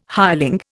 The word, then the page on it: hireling: Indian